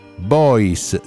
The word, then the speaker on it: Beuys: Beuys si pronuncia B-O-I-S.